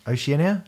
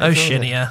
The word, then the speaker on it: Oceania: Oceania? That's not how you say it. – Oceania, I think.